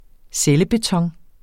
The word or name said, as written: cellebeton